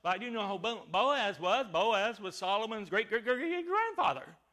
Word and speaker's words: Boaz: So Boaz, and it's pronounced, you know, Boaz, like this. Say in the Greek. I mean in the Hebrew. Bo, and then as. And then the second one he named, spelled this way, J-A-C-H-I-N.